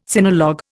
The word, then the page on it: sinologue: Indian